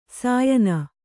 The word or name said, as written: ಶ್ಯಾಣ